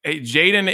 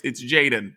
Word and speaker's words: Jxdn: Jaden. Jaden is a 19-year-old, like Travis Barker calls him his protege. His name is spelled JXDN, which is annoying.